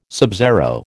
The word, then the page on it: subzero: American English